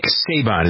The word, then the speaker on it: Saban: As a matter of fact, what I didn't know is that the state of Texas was prepared to offer Alabama coach Nick Saban, is that how you pronounce it, S-A-B-A-N? – I think so.